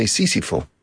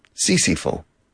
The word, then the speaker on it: Sísifo: How do you say Sisyphus in Spanish? You say Sísifo. Sísifo.